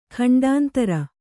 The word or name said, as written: ಖಂಡಾಂತರ